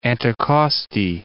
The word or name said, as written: Anticosti Island